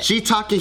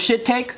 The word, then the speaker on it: shiitake: Shiitake, heitake. – Oh, you thought it was shit-take? – No, like shit-take. – Yeah, it's shiitake. That's why there's double eyes.